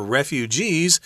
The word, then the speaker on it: refugee: Yeah, so if you're a refugee, and notice we put the accent on the end refugee, not refugee, refugee.